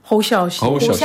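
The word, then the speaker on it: Hou Hsiao-hsien: Nämlich Edward Yang und Hu Xiaoxian. Nee, wie spricht man den aus? – Hou Xiaoxian.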